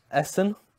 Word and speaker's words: Essen: Essen.